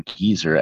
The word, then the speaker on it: GZR: GZR or Geezer, as I guess it's actually supposed to be pronounced,